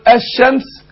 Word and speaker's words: الشمس: وبعدين عندنا اللام هنا اما اقول اللام هنا شمسية زي ما اقول الشمس ما بقولش الشمس.